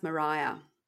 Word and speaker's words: Maria: Yeah, and it's spelt Maria, but it's pronounced Mariah.